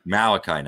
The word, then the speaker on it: Malachi: Your local guy, Malika Knight. Did I say that right? – Malachi Knight.